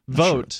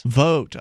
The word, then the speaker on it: Voat: Voat. – Vote. – Sure. Vote. – Rhymes with goat. – Vote.